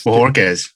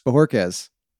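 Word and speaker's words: Bojorquez: Bajorquez? How do we say this? – Bohorquez. – Bohorquez. That's what I thought. – A lot of people get confused with the Quez. They want to say Quez.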